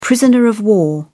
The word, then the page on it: prisoner of war: British English